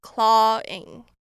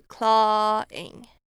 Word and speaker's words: clawing: 那我们第三个发音重点是 clawing。那这边的 AW 是发 R 的音，所以是 clawing。